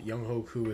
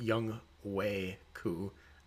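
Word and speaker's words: Younghoe Koo: Kickers, we only have 1 guy at the top, Young Hoku with 2. By the way, it's also, speaking of names that we mispronounce, it's not Young Hoku, it's Young Wei Ku.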